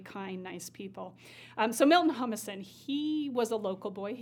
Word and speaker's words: Humason: Now, let's talk about his right-hand man, Milton Humason.